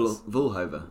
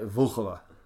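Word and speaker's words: Vlhová: I think that's Vlahova. – Vlahova. Mm-hmm. I think you don't pronounce the O. You don't stress the O.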